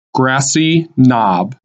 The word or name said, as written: Grassy Knob